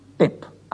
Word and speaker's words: Tepp: Nachdem der Tepp gleichen Wortstammes ist wie tappig, der Tappige aber bei uns mit einer gewissen Milde rechnen kann, wird der Tepp entgegen dem Duden wie Depp ausgesprochen. Die Taube kommt in der Aussprache als genau die gleiche Taum wie die Taube beim Eisschießen oder in der Fassbinderei.